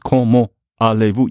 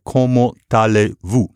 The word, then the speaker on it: comment allez-vous: But it's not pronounced comment allez-vous. You have to say comment allez-vous, like that.